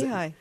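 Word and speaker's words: Nehi: And my father every once in a while would have a craving for knee-high soda, which I guess is that the correct pronunciation? – Yeah, knee-high, sure.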